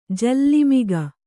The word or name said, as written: ಜೋಲುಮುಖ